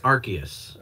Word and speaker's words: Arceus: Arceus, what is the actual pronunciation? – I believe it's Arceus.